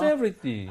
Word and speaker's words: everything: 발음할 때 everything이 아니라 전에 예, 했었던 th sound, 윗니 아랫니 사이에 혀가 아주 살짝만 낀다는 거.